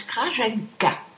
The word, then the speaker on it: Krajenki: Krajenki se prononce Krajenka.